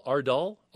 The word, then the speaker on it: Ardal: Mr. O'Hanlon, how do you say his first name? A-R-D-A-L. Ardal?